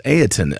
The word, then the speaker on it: Ayrton: Ayrton. It is spelled A-Y-R-T-O-N. It is like Ayrton Senna, and I do apologize for messing that.